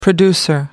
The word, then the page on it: producer: American English